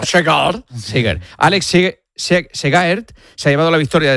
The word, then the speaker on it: Segaert: Segaert.